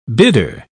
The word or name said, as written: bidder